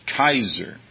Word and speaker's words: Kaiser: Kaiser, which is a literal pronunciation of the Latin word Caesar, which we read Caesar.